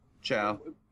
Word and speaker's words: Chao: Is it Chao or Chao? What? How do you say it? Do you know? – Chao. – It's Chao? – It's Chao.